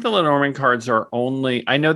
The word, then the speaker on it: Lenormand: I say Lenormand. Okay. Lenormand. Some people say Lenormand.